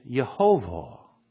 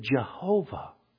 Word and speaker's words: Jehovah: The word that is specifically used right here is Yehovah. Now, in the Hebrew, they don't pronounce their J's, and we say it when we translate it, Jehovah. The word Lord there is Jehovah.